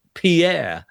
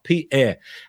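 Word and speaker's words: Pierre (פאר): The word beauty in Hebrew is pronounced Pierre. And it's not the French name Pierre, but it's a Hebrew word, which is P apostrophe E-R, Pierre.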